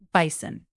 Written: bison

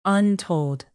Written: untold

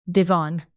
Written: divan

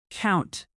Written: count

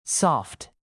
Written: soft

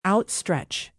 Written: outstretch

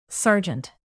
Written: sergeant

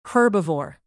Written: herbivore

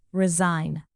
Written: resign

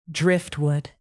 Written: driftwood

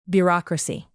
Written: bureaucracy

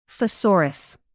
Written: thesaurus

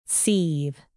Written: seethe

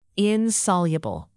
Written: insoluble

